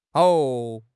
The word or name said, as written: hao3